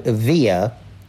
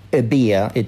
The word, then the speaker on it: Evia: And it was in an area between the Greek island of Evia, or Ebia, it's pronounced both ways, and the Greek mainland.